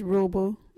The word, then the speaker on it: ruubu: local wiggity word is rooable. What is a rooable? Rooable is a word for rope. You can use rooable in this sentence. Yeah, get that rooable. Rooable is spelt R-U-U-B-U.